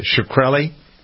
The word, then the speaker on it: Shkreli: Shkreli.